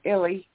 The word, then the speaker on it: ILY: I think I should have pronounced the title Illy instead of I-L-Y.